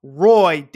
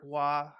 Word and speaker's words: Roy: It's V-I-D-E. Vide. – I don't know, man. – I don't like this. Only in America we pronounce it Patrick Roy, damn it. It's not Patrick Waugh.